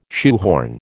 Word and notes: shoehorn: American English

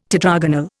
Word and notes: tetragonal: Indian

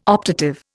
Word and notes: optative: Indian

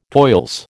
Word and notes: toils: American English

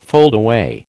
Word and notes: foldaway: American English

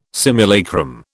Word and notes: simulacrum: British English

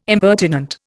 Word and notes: impertinent: Indian

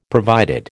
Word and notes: provided: American English

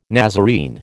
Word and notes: Nazarene: American English

